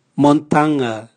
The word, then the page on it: montanga: Raga